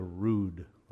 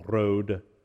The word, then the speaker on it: Rood: This one's probably about 1,100 years old, called The Dream of the Rood, or properly pronounced would be The Dream of the Road. It's an old English word for cross.